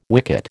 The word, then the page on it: wicket: American English